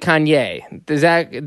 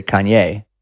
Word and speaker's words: Kanye: And you pronounce it Kanye. That's correct, correct? – I don't think there's the wrong way to say it, but definitely people in his circle would say Kanye.